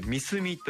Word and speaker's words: 三角: 三角と書いて「三隅」と読む三隅西港という港があるんですね。